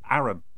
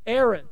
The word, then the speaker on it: Arran: You know, that the father had that castle on the island of Arran. You know, how do you pronounce that? What's the name of the Arran? – Arran.